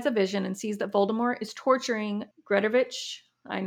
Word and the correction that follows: Gregorovich: All right, how do I say it? – Gregorovich.